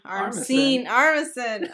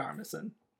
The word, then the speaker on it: Armisen: Played by Fred Armstein. Armisen. Oh my god, I cannot pronounce anybody's name. – That's all right. Fred Armisen.